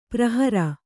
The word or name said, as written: ಪರಿಹರ